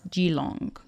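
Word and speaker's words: Keelung: It's spelled Keelong, but I believe it's pronounced Geelong.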